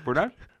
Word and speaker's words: Fort Dodge: That's how they say it. Fort Dodge? Fort Dodge? – Yeah, Fort Dodge. – The T never makes it.